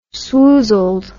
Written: swoozled

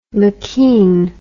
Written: McKean

